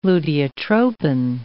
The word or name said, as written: luteotropin